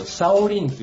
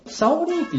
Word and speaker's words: 小林: 中国の人って、漢字の名が付いてる人のことを、向こうの読み方で呼ぶ、なんか、傾向があるんですけど、小林って中国語の読み方だと、シャオリンって言うんですよ。 – あ、シャオリンって言うんだ。 – そう。で、僕もシャオリンって呼びやすくていいなと。 – かっこいいよね。 – 僕も裏では、シャオリンって勝手に言ってるんですけど。